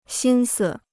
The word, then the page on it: 心思: xīn si